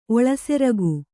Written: ಒಳಸೆರಗು

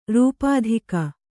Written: ರೂಪಾಧಿಕ

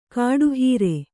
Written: ಕಡೆಹರಿ

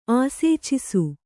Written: ಆಸೇಚಿಸು

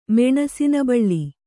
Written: ಮೆಣಸಿನಬಳ್ಳಿ

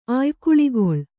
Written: ಆಯ್ಕುಳಿಗೊಳ್

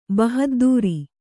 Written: ಬಹದ್ದುರಿ

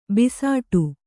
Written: ಭಿಸ್ಸಟೆ

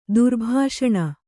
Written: ದುರ್ಬೆಸನಿ

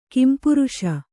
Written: ಕಂಪ್ರಿಸು

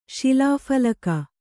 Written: ಶಿಲಾಫಲಕ

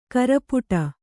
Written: ಕೂರ್ಪಿಡು

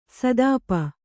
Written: ಸುತಪ

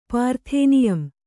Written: ಪಾರ್ಥೇನಿಯಂ

